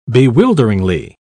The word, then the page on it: bewilderingly: British English